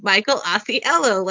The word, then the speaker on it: Michael Ausiello: And I remember when people uh, generally, and I'm not, I feel like Jane Jack or whoever, whoever, whatever lost podcast people would mispronounced his name, I would have been like right in and be like, no, Michael Ausiello, like hello or whatever.